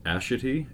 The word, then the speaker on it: Ashitey: Her name is Claire Hope Ashitey. A-S-H-I-T-E-Y. Like, it almost sounds like a joke name. Ashitey.